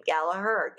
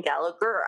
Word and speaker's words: Gallaher: I know I've done this multiple times with my own last name, Gallaher, Gallagher. People always call me Gallagher.